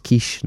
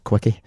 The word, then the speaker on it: quiche: For example, it's actually pronounced quiche, not quickie.